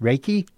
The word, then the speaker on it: Reiki: It's pronounced, I said Reiki, but it's pronounced Reiki. – Reiki. – Like with a rake. – Like you're raking.